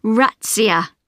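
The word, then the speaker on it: Razzia: Rats? – No, Ratzia. I'll spell it for you. It's R-A-Z-Z-I-A.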